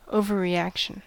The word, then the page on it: overreaction: American English